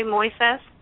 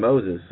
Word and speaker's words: Moises: Yeah, Moy, like Boy Moises. – Moises? Okay, yeah. – I was calling him Moses, sorry.